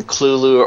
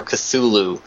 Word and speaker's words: Cthulhu: Well, I either use the pronunciation Clulu or Cthulhu. – Okay. – But it's okay one way or the other because it's actually meant to model something that's in a pre-human language.